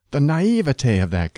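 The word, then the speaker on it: naïveté: Naivete combines naive and te, and standard word division calls for three syllables, naivete. Don't add a spurious fourth syllable. It is acceptable to stress the middle syllable, naivete, but the weight of authority favors the primary accent of the final syllable, naivete, which should rhyme with day, never with t.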